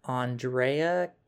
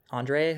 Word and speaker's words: Andrea: And this former store manager, she was 21 at the time, and Andrea, I'm pretty sure it's how you say his name, Andrea, who's a guy, he said his room was next door, which surprised the 21-year-old.